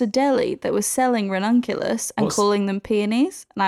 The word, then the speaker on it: ranunculus: Oh, ranunculus, that is an excellent choice. Excellent pronunciation as well of said flower.